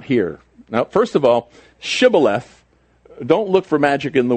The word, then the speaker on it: Shibboleth: If he said no, then they would say to him, then say Shibboleth. And he would say Sibboleth, for he could not pronounce it right.